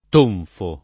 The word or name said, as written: tonfo